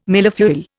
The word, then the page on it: millefeuille: Indian